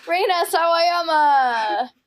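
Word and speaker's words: Rina Sawayama: Reina Sawayama.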